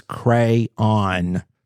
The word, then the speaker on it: crayon: It's crayon. Crayon. – Maybe not that drawn out, but crayon. – Crayon. Yeah. – Okay. – It's crayon. It's two syllables, not crayon.